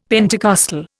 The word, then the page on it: Pentecostal: Indian